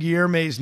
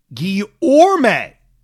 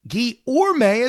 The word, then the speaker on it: Guillorme: I'm not sure if you're aware, but you pronounce Mr. Guillerme's name as Guillerme. All announcers and commentators I've heard pronounce his name as Guillorme. The Mets pronunciation guide lists it as Guillorme as well.